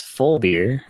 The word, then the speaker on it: Vollbier: It's pronounced full beer.